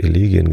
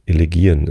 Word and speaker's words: Elegien: Ich habe Elegien gesagt, ne? War falsch. Elegien ist die richtige Aussprache.